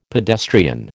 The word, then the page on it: pedestrian: American English